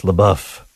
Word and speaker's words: LaBeouf: Patrick Alog says, it's pronounced LaBeouf. Yeah, sorry about that. Anyways, I guess I got too French with it.